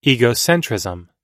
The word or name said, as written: egocentrism